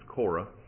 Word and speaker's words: Korah: Depends who you read after to see how that's pronounced, but I believe it's Korah.